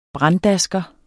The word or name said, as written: branddasker